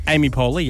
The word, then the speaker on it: Poehler: He recently spoke out about his struggles with alcohol on a podcast with Amy Poehler. Is that how you say her name? – Amy Poehler, yeah.